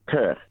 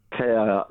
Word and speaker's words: Kirk: Kerr. – How do you spell that? – Kerr.